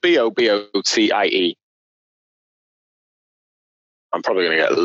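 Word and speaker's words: bobotie: How do you pronounce that then? – Bobo tie. Bobo tie.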